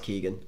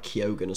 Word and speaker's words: Keoghan: I always say Keegan, but I don't think it's pronounced Keegan. I think it's like Keoghan or something like that.